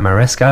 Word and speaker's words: Maresca: And from the manager that they're looking at, Maresca, have I pronounced his name right? – Yeah, he was ex-Chelsea manager.